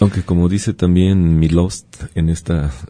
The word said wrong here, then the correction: Czesław Miłosz: Czesław Miłosz.